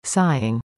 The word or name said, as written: sighing